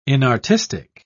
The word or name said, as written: inartistic